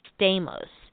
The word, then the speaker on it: Stamos: I mean, it's spelled just like John Stamos, so yeah. I assume that it's pronounced that way.